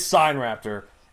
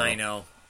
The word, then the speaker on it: Sinraptor: It is Sinraptor. It means Chinese thief, and that's how they pronounce it. So, Sino. – Because it's from China.